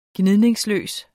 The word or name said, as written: gnidningsløs